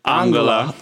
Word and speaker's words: Angela: Angela.